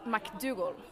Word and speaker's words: McDougal: Det rett hvis jeg sier McDonald's? – Nei, det nok McDougal.